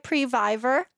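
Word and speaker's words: previvor: So am I saying it right? Previver? – Yes. – Like survivor, Previver.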